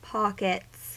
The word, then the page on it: pockets: American English